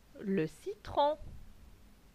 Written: Le citron

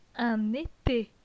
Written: Un ete

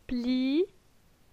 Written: Pli

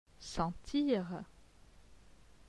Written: sentir

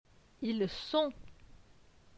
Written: Ils sont

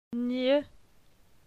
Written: Gn

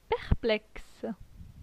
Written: perplexe

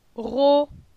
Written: Ro

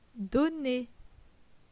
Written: Donner

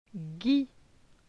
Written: Guy

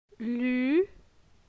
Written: Lu

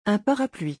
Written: Un parapluie

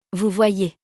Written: vous voyez

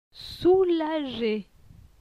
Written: soulagé

